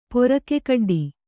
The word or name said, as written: ಪೊರಕೆಕಡ್ಡಿ